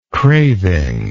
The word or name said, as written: cravingness